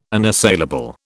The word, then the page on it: unassailable: British English